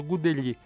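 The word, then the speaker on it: Gudelj: Σου άρεσε τον Γκούντελι, είναι έτσι, έτσι προφέρεται, τι να κάνουμε.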